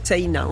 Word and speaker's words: Seina: En fait, ça s'écrit un peu comme la Seine, mais... – Voilà, avec un A à la fin, Seina, on prononce le I aussi. – Comme si on avait un tréma au-dessus.